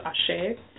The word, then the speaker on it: Axé: That's called Axé in Brazil. It's spelled A-X-E, so you'll see that a lot.